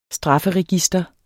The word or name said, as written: strafferegister